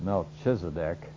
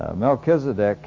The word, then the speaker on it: Melchizedek: That's not pronounced Melchizedek, though sometimes we pronounce CH that way, but if you have a self-pronouncing Bible, and you may have a line, as mine does, under the CH of Chedorlaomer, and also Melchizedek. So CH most of the time in our Bibles is pronounced like a K, like Christ, for example, or Malachi.